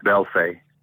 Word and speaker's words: Belfay: Oh, it's pronounced Belfay. – Belfay. – B-E-L-F-A-Y. – Yeah.